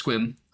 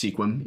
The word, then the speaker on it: Sequim: I think it was Squim, uh, and which I learned through the comments is not pronounced Sequim, even though it's spelled Sequim. There